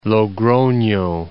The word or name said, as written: Logrono